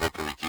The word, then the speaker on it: Okereke: His first half of his career, he was Bobby Okereke. And then he decided, you know, it was not pronounced that way. And he called himself Okereke, and then it was going to be Okereke.